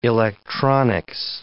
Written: electronics

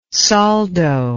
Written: soldo

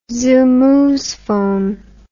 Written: zoomoozephone

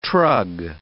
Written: trug

